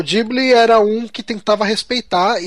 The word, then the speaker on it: Ghibli: a pronúncia correta é, é Ghibli, é Ghibli. mesmo.